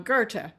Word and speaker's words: Goethe: That was called Breaking Barriers and was the recipient of, I always pronounce him wrong, Goethe, right? Is that right? – Right.